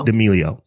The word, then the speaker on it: D'Amelio: D'Amelio.